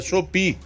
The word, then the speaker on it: Shopee: Como eu não acesso essa, essas empresas na internet, eu fico até meio perdido em relação à pronúncia. É Shopee, tá?